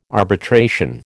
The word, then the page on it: arbitration: American English